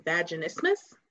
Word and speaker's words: vaginismus: So talk to us about your dissertation in vaginismus.